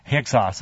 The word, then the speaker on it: Hyksos: Anyway, Hyksos.